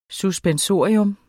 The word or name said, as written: suspensorium